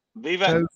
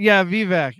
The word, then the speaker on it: Vivek: That Vilsack guy, the liberal or Vivek? – Vivek.